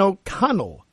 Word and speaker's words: O'Connell: When we look at Kevin O'Connell, just said it right this time, finally, Julie.